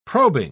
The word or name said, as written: probing